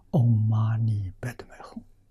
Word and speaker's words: 唵嘛呢叭咪吽: 唵嘛呢叭咪吽，啊，这是西藏读音。